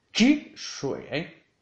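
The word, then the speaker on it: chi shui (汽水): Vamos a por refresco, que en chino se dice chi shui, chi shui, chi shui. Con un cuarto tono en la i de chi y un tercer tono en la i de shui, chi shui.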